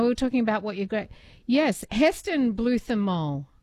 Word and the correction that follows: Blumenthal: Heston. Yes, Luke. Did I absolutely butchered that. – Heston Blumenthal.